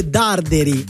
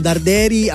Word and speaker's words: Darderi: Ringrazio Michele da Andrea che mi fa notare che l'accento è sulla A e non sulla, quindi è Darderi e non Darderi.